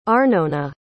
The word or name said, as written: Arnona